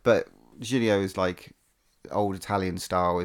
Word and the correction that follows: giallo: Giallo, is that how you say it? – Yeah, Giallo. Oh, Giallo.